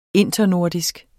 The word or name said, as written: internordisk